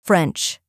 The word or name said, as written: French